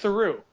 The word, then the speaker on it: Theroux: His father is Paul Theroux, and you pronounce it Theroux, like true.